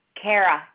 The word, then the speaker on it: Kara: Kara Challingsworth. – Did you say your first name is Paris? – Kara, K-A-R-A. – How do you spell that last name? – C-H-A-L-L-I-N-G-S-W-O-R-T-H.